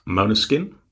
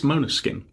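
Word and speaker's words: Måneskin: And this week, it's Maneskin, depending on your pronunciation. But officially, it's Maneskin.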